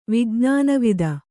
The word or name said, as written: ವಿಜ್ಞಾನವಿದ